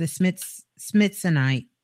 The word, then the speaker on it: Smithsonite: It's S-M-I-T-H-S-O-N-I-T-E, Smithsonite.